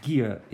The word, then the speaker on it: gear: So please pay attention to the pronunciation of the second word, gear. It's a G sound, not J.